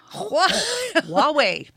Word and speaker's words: Huawei: Huawei. Perfect. – Yes, the H is a W. Let's just do that right out of the gates. – Okay. – So the Huawei P20 has completely transformed the smartphone world.